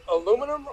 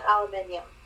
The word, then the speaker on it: aluminium: Do you pronounce it aluminum or aluminium? – It is 100% aluminium.